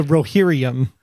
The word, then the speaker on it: Rohirrim: How do you say it? – The Rohirrim.